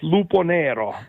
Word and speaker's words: Luponero: Jag måste ju fråga Marco, hur uttalar jag ditt efternamn? Du är väl tokigt, va? Du får säga själv. – Nej, det är lugnt. Det är lugnt. Det kan vara lite svårt. Luponero.